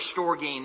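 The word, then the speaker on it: storge (στοργή): And the first word we want to think about is the Greek word storge. And that's how that's pronounced, storge, or at least that's my understanding of how it's pronounced.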